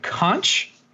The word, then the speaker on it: conch: You call it conch? – Conch. – It's a K.